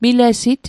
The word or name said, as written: milesit